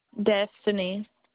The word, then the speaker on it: Destiny: Destiny. – Spell that, please. – D-E-S-T-I-N-Y.